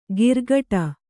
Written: ಗಿರ್ಗಟ

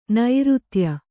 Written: ನೃತ್ಯ

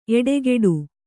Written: ಎಡಗಡೆ

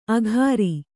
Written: ಆಖರಿ

